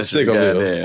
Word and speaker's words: Seagoville: That's how they say it, Seagoville.